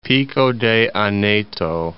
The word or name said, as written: Aneto, Pico de